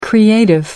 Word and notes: creative: American English

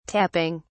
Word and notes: tapping: British English

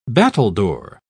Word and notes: battledore: British English

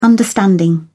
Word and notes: understanding: British English

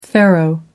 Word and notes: pharaoh: American English

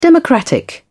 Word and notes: democratic: British English

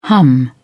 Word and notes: hum: British English

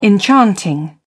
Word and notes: enchanting: British English

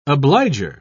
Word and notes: obliger: British English

